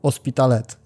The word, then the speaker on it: Hospitalet: czyli Andora i Hospitalet. Nie czyta się H w hiszpańskim, tak, prawda?